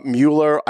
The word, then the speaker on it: Mueller: You're pronouncing it like Bueller, like Ben Stein. – Mueller, and remember, Ben right.